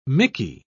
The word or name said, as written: mickey